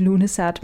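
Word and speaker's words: Lughnasadh: Ja, det udtales Lunesat, men det staves på en meget forvirrende måde, og ja, du kan se, hvordan det staves her i podcastens titel.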